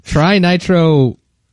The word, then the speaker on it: Trinitrotoluene: What is the chemical tritoluene? I totally butchered that. Trinit. I cannot pronounce this. – Okay. – Trinitrotoluene.